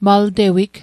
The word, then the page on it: maltewig: mal'tewik; Mi'gmaq